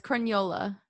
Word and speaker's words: Crugnola: How's it actually pronounced? – It's Crugnola. – Crugnola. I should have asked you first. – The end is like lasagna.